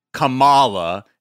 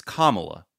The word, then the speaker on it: Kamala: It's not Kamala, it's Kamala. Okay. – It's Kamala.